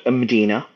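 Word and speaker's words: Mdina: It's Malta's old capital called Medina. It's spelled Medina. – Right. – And that's like you would find in Morocco, but that's because of its Arab roots. It's the same Medina. It's just said slightly differently.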